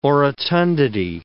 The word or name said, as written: orotundity